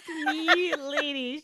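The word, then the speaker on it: Melady: Straight up pronounced Milady.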